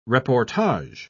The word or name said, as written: reportage